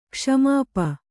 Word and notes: ಕ್ಷ್ಮಾಪ: Kannada

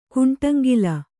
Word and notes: ಕುಂಟಂಗಿಲ: Kannada

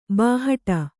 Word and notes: ಬಾಹಟ: bāhaṭa

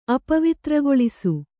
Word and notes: ಅಪವಿತ್ರಗೊಳಿಸು: Kannada

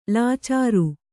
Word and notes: ಲಾಚಾರಿ: lācāri

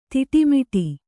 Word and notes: ತಿಟ್ಟಮಿಡು: Kannada

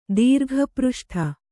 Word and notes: ದೀರ್ಘಪೃಷ್ಠ: kannada

